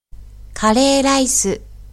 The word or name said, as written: kare-raisu